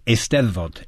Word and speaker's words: Eisteddfod: Eisteddfod? Am I saying that right? – You're close. Eisteddfod. – Eisteddfod. I wasn't that close. – Well, everyone says it wrong except for Welsh speakers, so you were good.